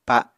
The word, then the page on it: pa7: Apatani